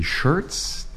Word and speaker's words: Schertz: A warm welcome to Mark Roberts from Schertz Auto Service in Schertz, Texas. Hey, hi. – Oh, good morning. – Good morning. – I'm glad to be here. And you pronounced our city name correctly? – Oh, wow.